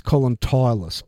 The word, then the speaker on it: Tyrus: Incorrect pronunciation. – Oh? – It's Colin Tyrus, because he has been tireless in his devotion and patience with us.